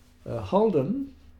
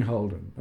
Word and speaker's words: Haldon: Was Peter Waite's chauffeur, and I do remember seeing Holden. I mean, that was I discovered later that it wasn't spelled Holden; it was spelled Haldon, but the way people pronounced it.